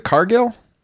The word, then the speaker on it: Cargill: Is it Cargill? – Yes. – Am I saying it right? Yeah.